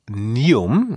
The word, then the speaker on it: NEOM: Denn der dänische Logistikkonzern, der wird Partner des saudi-arabischen Infrastrukturprojekts NEOM, so wird's ausgesprochen, also aber geschrieben N-E-O-M.